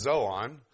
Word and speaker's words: zoon: Now, this word beast is actually from the Greek word zoon, Z-O-O-N, but it's pronounced zoon. Actually, if I could say it right, you'd hear a D-Z-O, zoon, but, and so on.